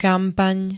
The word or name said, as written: kampaň